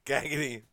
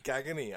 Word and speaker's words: Cagney: the other guy, as always, Cagney Larkin. – Oh, Cagney? – Cagney. Threw an extra fucking syllable in my name. – Ooh, what is Cagney? – Cagney. I am Cagney Larkin.